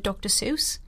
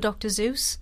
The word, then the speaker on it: Seuss: because he just keeps mispronouncing the word Dr. Seuss Yeah. – for Dr. Zeus.